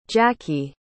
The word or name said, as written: jakie